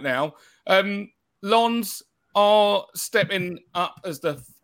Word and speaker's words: Lens: we move on to Lons? Lons. Yeah. yeah. Your your pronunciation is getting better as we go on.